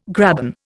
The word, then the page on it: graben: Indian